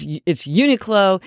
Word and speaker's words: Uniqlo: And I had to look up how to pronounce Uniqlo, because I've been mispronouncing it all these years, but it's Uniqlo.